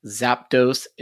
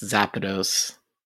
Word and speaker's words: Zapdos: It is not Zapdos, it's Zapdos. – The actual pronunciation. – The official pronunciation is Zapdos.